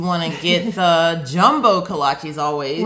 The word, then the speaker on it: kolaches: Pronounced kolaches.